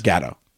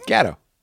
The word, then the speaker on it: Gatto: And it's Gatto. Gat, like G-A-T. – That's what I said. – Gatto.